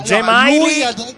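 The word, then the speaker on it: Džemaili: La pronuncia corretta dovrebbe essere perché lo sentivo pronunciare in due nomi, in due modi. – 10.000 modi. – No, Gemaili. – Lui ha sempre detto Gemaili. – Gemaili, esatto.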